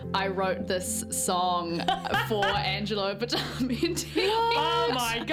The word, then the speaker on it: Angelo Badalamenti: Angelo Badalamenti. – Badalamenti.